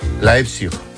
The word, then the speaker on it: Leipzig: ¿Lo he dicho bien? – Qué importante saber idiomas. – Tengo aquí a mi amigo que me lo dice. ¿Cómo se dice? – Leipzig. – Leipzig. – Leipzig. – ¿Ves? – Leipzig. Muy bien.